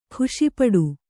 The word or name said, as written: ಖುಷಿಪಡು